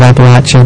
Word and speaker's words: Appalachia: Well, this year it was in Southern Appalachia, however, it depends on how far north you are, how you pronounce that, but they call it Appalachia down there.